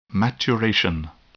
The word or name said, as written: maturation